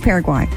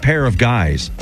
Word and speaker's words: Paraguay: It is Paraguay. – Yeah, Paraguay. – Not pair of guys, like you said the first time.